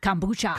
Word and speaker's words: kombucha: Kombucha.